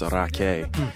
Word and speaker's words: Rakei: So it's the last name is spelled R-A-K-E-I, but it's actually Maori, so it's pronounced Raque.